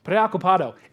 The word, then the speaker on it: preocupado: Preocupado. Oh, good. Gracias. Preocupado.